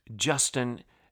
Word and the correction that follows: Justine: Justine Ang Fonte. I'll say it one more time. I mispronounced her first name and said Justin. It's Justine Ang Fonte. I'll spell it for you. Justine A-N-G Fonte. F-O-N-T-E.